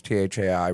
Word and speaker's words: Thai: Any way you spell it, it'll work. It's Tai Rivera, though, but however you do it, Google will spit it back.